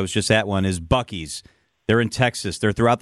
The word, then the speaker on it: Buc-ee's: They are a big company with a lot of money, and they market themselves as Buc-ee's.